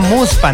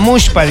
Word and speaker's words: muspa: Se pronuncia muspa, no mushpa, dice.